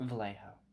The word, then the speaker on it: Vallejo: Elizabeth relocated to Vallejo, California. Is that how you say it? – Vallejo.